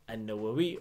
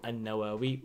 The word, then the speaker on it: An-Nawawi: Imam An-Nawawi or An-Nawawi, whichever way you want to pronounce it, they're both accepted.